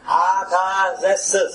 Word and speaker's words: Artaxerxes: Artaxerxes, that's his name.